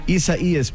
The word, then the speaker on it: Isaias: Isaias, but thank Ichabod's you so better.